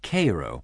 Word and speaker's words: Cairo: I headed for Cairo, which is spelt the same way as Cairo, but is pronounced Cairo. I don't know why.